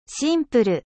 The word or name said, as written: simple